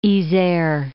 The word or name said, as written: Isere